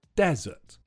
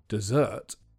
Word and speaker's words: desert: The only thing to be careful about with this word is the stress. It's on the first syllable, desert. If you say the word with the stress on the second syllable, dessert, then it means the sweet course you sometimes eat at the end of a meal.